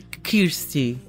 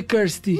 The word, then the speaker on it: Kirstie: É Kirsty. Ela dizia Kirsty.